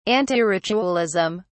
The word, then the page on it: antiritualism: British English